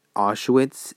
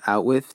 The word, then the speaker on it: Auschwitz: In chapter 16, that's when his sister Gretel finally pronounces the word Auschwitz instead of Outwiff to him, but it's already stuck in his mind that it's called Outwiff because that's how he saw it and that's how he pronounced it.